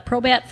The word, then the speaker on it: Probat: We're upstairs at the brewery right now, but on the lower level in the corner, you can walk in and see the Probat. Am I saying that correctly? – Yep. It's a Probat from Germany.